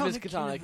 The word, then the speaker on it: Miskatonic: I don't know how it's pronounced. – It's Miskatonic. Yeah.